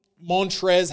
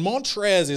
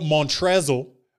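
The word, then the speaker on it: Montrezl: And then Mon Montrez Harrell. And Montrez is spelled M-O-N-T-E, sorry, M-O-N-T-R-E-Z-double-L, right? Is it's spelled Montrezel, but his name's just Montrez. And it's like, well, don't add the double L.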